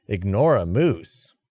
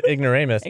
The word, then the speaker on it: ignoramus: Like, my cousin pronounced, she was reading, she's like, ignore a moose. – Okay, ignore a moose. – And then, it's, you know, ignoramus.